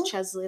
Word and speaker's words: Chesley: Is it Chesley? I feel bad. Yeah. I don't want to say her name wrong. – I think wrong. – It is Chesley. That's how I was pronouncing it.